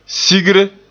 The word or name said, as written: sigre